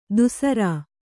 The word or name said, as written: ದುಸ್ಸರ